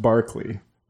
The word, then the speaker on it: Berkeley: It's a long, like I said, I didn't know until later that it's Berkeley is pronounced Barkley.